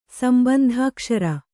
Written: ಸಂಬಂಧಾಕ್ಷರ

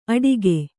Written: ಅಡಿಕೆ

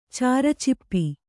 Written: ಚಾರಚಿಪ್ಪಿ

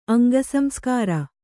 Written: ಅಂಗಸಂಸ್ಕಾರ

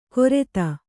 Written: ಕೊರೆತ

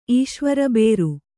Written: ಈಶ್ವರಬೇರು

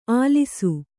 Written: ಆಲಿಸು

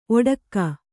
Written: ಓಡಿಕೆ

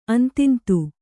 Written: ಅಂತಿಂತು

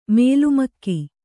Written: ಮಲಮಗ

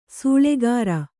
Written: ಸೆಳ್ಳುಗುರು

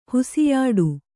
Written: ಹುಸಿಯಾಡು

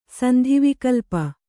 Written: ಸಂಧಿವಿಕಲ್ಪ

